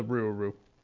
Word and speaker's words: Rougarou: No, I mean, it's pronounced the Rougarou.